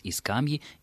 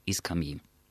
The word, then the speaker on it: скамьи: Все орфоэпические справочники допускают оба ударения как равноправные. То есть мы можем сказать «и скамьи», «и скамьи».